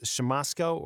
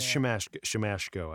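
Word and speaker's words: Siemaszko: Is it pronounced Shamosko or Shamashko? I believe it is Shamashko. I did just Shamashko. Oh, yeah. Okay. Shamashko. – According to a couple of places. – I apologize. Shamashko.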